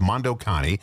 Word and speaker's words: Mondo Cane: Yeah, it's spelled Mondo Cane, C-A-N-E, but the movie was pronounced Mondo Cani.